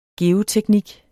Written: geoteknik

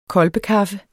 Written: kolbekaffe

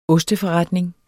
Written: osteforretning

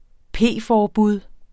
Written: p-forbud

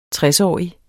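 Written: TRESÅRIGT

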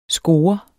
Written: scoret

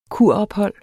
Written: kurophold

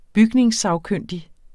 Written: bygningssagkyndig

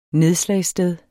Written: nedslagssted

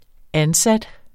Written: ansats

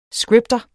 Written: scripter

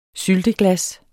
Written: sylteglas

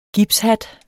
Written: gipshat

